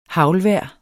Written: haglvejr

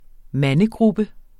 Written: mandegruppe

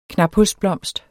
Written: knaphulsblomst

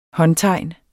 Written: håndtegnet

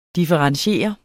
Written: differentiere

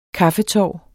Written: kaffetår

